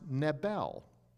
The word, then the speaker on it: Nebel: It's pronounced Nebel.